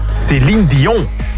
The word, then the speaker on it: Céline Dion: Céline Dion. Met een goede uitspraak ook.